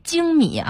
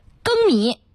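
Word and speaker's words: 粳米: 还有啊那个精米啊，大部分人都会读成精米，但现在人不能叫精米了、嗯、现在要叫更米。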